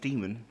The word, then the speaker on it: Daemon: Yeah, D-A-E-M-O-N. – Yeah, they call him Matt Damon in Cornwall, like everywhere else. – It's still pronounced Demon.